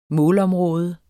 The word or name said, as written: målområde